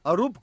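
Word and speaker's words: Aroop: Arup Gupta.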